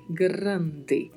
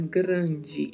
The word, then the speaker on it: grande: Grande, que tem o mesmo significado do português, a mesma escrita do português, mas a pronúncia é diferente. Observe que ao final da palavra falamos de, grande. A gente nunca vai falar grande em espanhol, porque este som do de não existe.